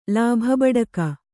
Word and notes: ಲಾಭಬಡುಕ: lābha baḍuka

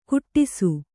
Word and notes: ಕುಟ್ಟಿಸು: Kannada

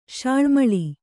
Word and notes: ಶಾಳ್ಮಳಿ: śaḷmaḷi; kannada

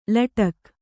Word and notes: ಲಟಕ್: laṭak

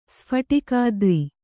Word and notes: ಸ್ಫಾಟಿಕಾದ್ರಿ: kannada

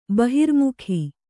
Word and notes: ಬಹಿರ್ಮುಖ: bahirmukha

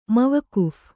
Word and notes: ಮವಕುಫ್: mavakuph; kannada

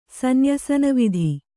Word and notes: ಸನ್ಯಸನವಿಧಿ: kannada

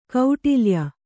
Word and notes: ಕೌಟಿಲ್ಯ: kauṭilya

